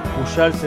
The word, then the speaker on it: Pujals: Facebook, todo con mi nombre, Pablo Pujals. Pujals es como se pronuncia, pero Pujals con J y seguramente lo suben.